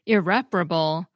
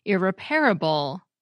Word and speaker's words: irreparable: For example, irreparable seems to be changing from four syllables with a main stress on the second, irreparable, to five syllables with the main stress on the third, irreparable.